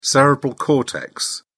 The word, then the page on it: cerebral cortex: British English